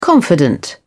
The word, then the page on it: confidant: British English